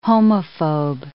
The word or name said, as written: homophobe